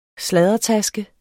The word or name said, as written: sladdertaske